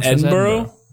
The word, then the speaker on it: Edinburgh: But it's spelled Edinburgh. – That's pronounced Edinburgh? – It's Edinburgh. Yeah.